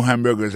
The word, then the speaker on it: hamburger: N'a pas remarqué que dans mot hamburger, ça, comme t'as dit, il y a trois syllabes là-dedans, coto appuyé, puis plus ça, c'est sous premier syllabe là.